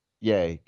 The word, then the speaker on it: Ye: Yay, because one syllable is better than two.